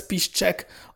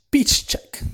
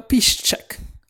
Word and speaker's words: Piszczek: I mean, I wouldn't mind having Mats Hummels and Lukas Piszczek. Only Lukasz Piszczek. When I moved here to Poland, people correct me on so many pronunciation stuff. So, Lukasz Piszczek, yeah. So, Piszczek. Well, Piszczek.